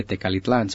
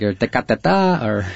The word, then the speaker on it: Tecalitlán: People can't even pronounce it, like Tecateta, and so that's the claim to fame, for that little town.